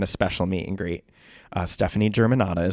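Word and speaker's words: Stefani Germanotta: Stephanie Germanotta.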